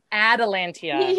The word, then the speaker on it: Atalantia: You guys yeah, give me your sisters anxiety every time you call her Atlantia. – What's her name? People are gonna listen to this and be like, they're wrong. What's her name? How do you say it? Atalantia.